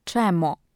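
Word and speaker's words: czemú: Ostatnio skończyłam na słowie czemu, pisanym z użyciem u kreskowanego, które czyta się raczej czemo.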